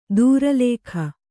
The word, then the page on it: ದೂರಲೇಖ: dūra lēkha